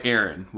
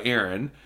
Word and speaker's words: Erran: I go by Aaron, which is spelled E-R-R-A-N.